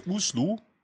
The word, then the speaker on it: Oslo: Äh, nee, aber das liegt einfach daran, dass die Aussprache des äh, O's, also das, was sozusagen de, der O-Buchstabe im Deutschen ist, mhm. ist ein U, wird als U ausgesprochen. Also er sagte Uslu.